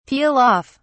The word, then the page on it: peel off: British English